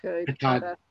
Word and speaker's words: Katahdin: Katahdin.